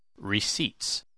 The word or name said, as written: receipts